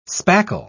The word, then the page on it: spackle: British English